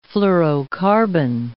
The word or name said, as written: fluorocarbon